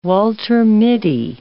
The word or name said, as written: Walter Mitty